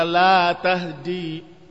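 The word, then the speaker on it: la tahdi (لَا تَهْدِي): Innaka wa innaka latahdi. Tapi yang tadi, innaka la tahdi, lamnya panjang dibacanya, la tahdi. Maka jangan salah baca.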